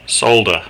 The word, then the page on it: solder: Australian English